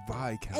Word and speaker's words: Viscount: It's pronounced Viscount. – And why is the S silent? – Goodbye, then.